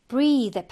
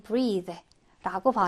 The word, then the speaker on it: breathe: 그 다음에 breathe 발음을 좀 주의해야 되고요. breathe 이로 끝나죠. 그럼 동사로 숨을 쉬다인데요. 명사는 끝에 이가 없어. 그 발음도 breath 좀 달라요. 그래서 얘가 숨, 호흡이 되고 동사는 breathe라고 발음을 합니다.